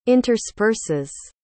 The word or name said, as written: intersperses